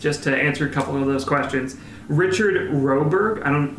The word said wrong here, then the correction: Roxburgh: It's Richard Roxburgh is the actor that plays Dracula in the film with Hugh Jackman from...